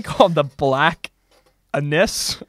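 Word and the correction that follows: Annis: I think it's pronounced Anise.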